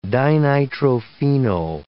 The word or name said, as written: dinitrophenol